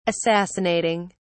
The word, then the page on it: assassinating: British English